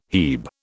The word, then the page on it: hebe: British English